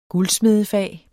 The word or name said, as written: guldsmedefag